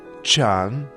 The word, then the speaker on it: 尝: Чан произносится во втором тоне.